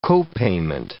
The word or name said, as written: co-payment